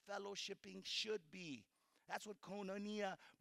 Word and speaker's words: koinonia: That word fellowship in Greek is pronounced koinonia. Can you guys say that with me? Koinonia.